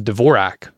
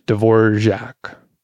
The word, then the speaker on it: Dvorak: This one's called a Dvorak or Dvorak, which I'm told that's the right way to pronounce it,